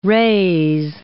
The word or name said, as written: rase